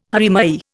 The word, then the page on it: haere mai: Indian